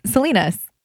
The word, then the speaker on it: Salinas: How do you say it? – It's Salinas. – Salinas. – Salinas. – It's Salinas. – Just Salinas.